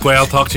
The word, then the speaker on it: Gaeltacht: The Gaeltacht. The Gaeltacht. – Gaeltacht. Gaeltacht.